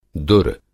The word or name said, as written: दुर